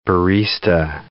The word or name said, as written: barista